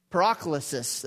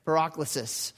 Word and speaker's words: Paraklesis: Paraklesis, that's it. You have a long A there. Paraklesis.